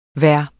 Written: hver

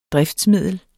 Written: driftsmiddel

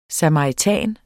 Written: SAMARITAN

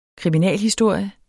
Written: kriminalhistorie

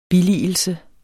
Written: billigelse

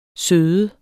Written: sød

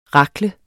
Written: rakle

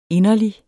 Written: INDERLIGSTE

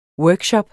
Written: workshop

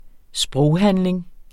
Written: sproghandling

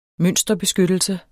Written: mønsterbeskyttelse

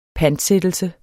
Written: pantsættelse

